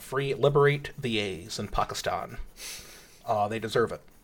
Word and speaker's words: Pakistan: It was a TED talk from Pakistan. – Okay. – That's the right way to say it, by the way. – Is it? – It's 2020. Gotta let the A's breathe.